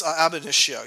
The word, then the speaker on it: ab initio: I really like the story, I'm not sure I'm going to say it right, Ab Initio? – Yes, Ab Initio, yeah.